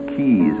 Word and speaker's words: quays: Or it can observe the movements of the craft in the harbor, the forest of masts at the quays of New York, or Keys, as it's better pronounced,